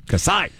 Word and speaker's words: Kasai: Okay, Kasai is a two-syllable Japanese name. – Okay, all right. – So it makes more sense now. – Yes, makes much more sense. I didn't know if it was just something people made up or... – Should be Kasai. – If it was from a TV show. – Emphasis on the last syllable.